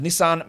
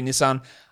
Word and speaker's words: Nissan: People said, it's pronounced Nissan because it's Japanese. I know. I know that, but the literal company in Australia, sorry, Nissan, the literal company in Australia on all of their advertising calls it Nissan.